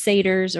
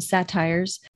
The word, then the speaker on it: satyrs: so while all those satyrs, or how I uh, incorrectly pronounce them, satires,